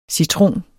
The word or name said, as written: citron